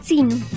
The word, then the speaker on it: tzinu: Atrás en náhuatl se dice tzinu. – Tzinu. – Tzinu. – ¿Y cómo se deletrea en español? – Es T-Z-I-N-U.